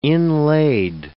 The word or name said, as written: inlaid